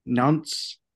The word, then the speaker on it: Nantes: Nantes is how you pronounce the name.